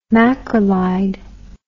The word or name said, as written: macrolide